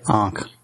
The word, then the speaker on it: Ankh: Ankh.